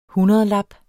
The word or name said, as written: HUNDREDLAPPER